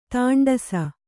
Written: ದಾಂಟಿಸು

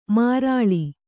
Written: ಮಾರಾಳ್

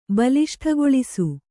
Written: ಬಲಿಷ್ಠಗೊಳಿಸು

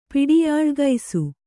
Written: ಪಿಡಿಯಾಳ್ಗೈಸು

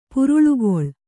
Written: ಪುರುಳ್ಗೊಳ್

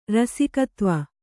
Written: ರಸಿಕತ್ವ

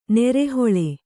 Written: ನರಹುಳು

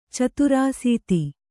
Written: ಚತುರಾಸೀತಿ

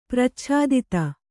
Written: ಪ್ರಚ್ಛಾದಿತ

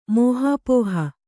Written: ಮೋಹಾಪೋಹ

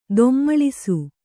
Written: ಧೂಮಲಿಸು

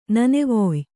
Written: ನನೆವೊಯ್

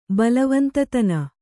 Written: ಬಲವಂತತನ

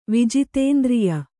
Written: ವಿಜಿತೇಂದ್ರಿಯ

